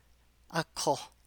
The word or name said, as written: ’áko